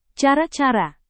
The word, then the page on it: cara-cara: Indonesian